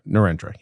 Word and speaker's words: Narendra: Is that how you say his name? Narendra, yes.